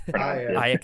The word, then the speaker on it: Aya: And with the performances, obviously, like great performance from yourself, is it Aya or Aya? I always don't know how to pronounce it. – Aya Cash and Chris Redd from SNL.